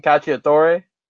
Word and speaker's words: cacciatore: can't pronounce pronunciations like I think I learned that it's pronounced like, for example, chicken cacciatore.